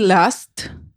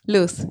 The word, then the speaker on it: Luz: Luz, yes. It's yeah. Just, I say loser, but just take off ER. So Luz.